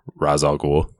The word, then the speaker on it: Ra's al Ghul: And these are obviously not all villains in the film, but there's Ra's al Ghul, that's how it's pronounced in that.